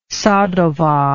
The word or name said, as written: sadowa